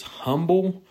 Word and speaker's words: humble: Now, there has been a discussion between my wife and I as to whether or not it is humble or humble. And I think I usually say humble, and she says humble because it has an H. And I try to convince her that it has a silent H.